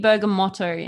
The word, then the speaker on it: bergamot: So he also told us that it is in fact pronounced bergamot, not bergamo. The T is not silent. It is actually bergamotto in Italian. So you do pronounce it bergamot for those wondering.